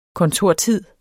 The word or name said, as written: kontortid